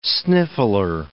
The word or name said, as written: sniffler